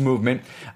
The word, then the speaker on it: MVMT: It's MVMT, but it's pronounced Movement.